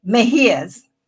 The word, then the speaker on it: Mejias: Mejias.